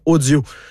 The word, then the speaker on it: OHdio: On vous invite à passer par la bulle de conversation de l'application audio de Radio-Canada, l'application sur laquelle vous pouvez retrouver la diffusion de cette émission et bien d'autres choses. Ça s'écrit O-H-D-I-O.